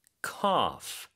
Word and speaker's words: cough: I have a pretty bad cough. C-O-U-G-H. – 네, C-O-U-G-H이고요. G-H가 이렇게 끝에 올 때는 보통 F로 발음을 하죠. 그래서 cough이고요.